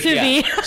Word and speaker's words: sous vide: Yeah, it's sous vide.